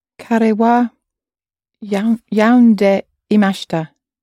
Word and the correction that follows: yonde: Very close with your pronunciation. Yonde imashita.